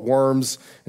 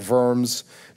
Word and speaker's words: Worms: we call it Worms. In Germany, they're going to pronounce it like Worms, just down the road from Mainz.